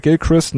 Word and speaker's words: Gilchrist: Habe ich wahrscheinlich falsch ausgesprochen? Kein Unbekannter auf jeden Fall in der Szene, Gary Gilchrist. Hätte ich ihn jetzt auch ausgesprochen. Kann natürlich sein, dass er in manchen Regionen auch Gilchrist genannt wird. Aber Gilchrist hätte ich jetzt auch gesagt.